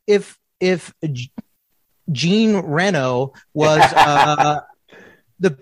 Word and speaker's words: Jean Reno: Jean Reno, as we say in America.